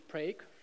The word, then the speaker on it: Prague: They told me that the correct pronunciation is Prague.